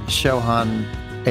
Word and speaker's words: Chauhan: So tell me about that first restaurant, Chauhan Ale and Masala House.